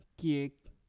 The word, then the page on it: kiek: Mpur